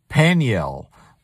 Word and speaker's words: Peniel: He called it Peniel, P-E-N-I-E-L, Peniel. I heard the other day a person say Peniel. No, please don't do that. Don't mispronounce it.